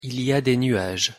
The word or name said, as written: il y a des nuages